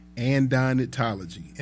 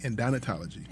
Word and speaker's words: Adonitology: It's called Andonitology. And is a religion where followers worship big curvy women's butts. – Wait, where is this at? – It's called it's spelled ADONITOLOGY. Andonitology. Andonitology.